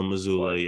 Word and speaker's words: Mazzulla: I think it's Mazzulla. – Joe Mazzulla, yeah.